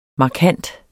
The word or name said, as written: markant